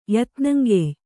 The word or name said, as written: ಯತ್ನಂಗೆಯ್